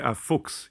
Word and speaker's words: Fuchs: Now, how do you pronounce this? Is it Fuchs? – Faux. – F-U-C-H-S? – Ah, Fuchs, yeah. – Fuchs. – Which is fox, okay. German for fox.